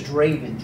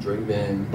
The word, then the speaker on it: Drvvn: So if you spell it, it's D R V V E N. My name is Draven. Draven. But, you know, it's an upside down V.